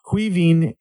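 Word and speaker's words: Caoimhín: My team of the week this week, I went with a 4-3-3. Starting keeper, I had Queven Kelleher. His name does not even look like pronounced like it's spelled, but no, it's actually the Irish derivative of Kevin, but who would have figured?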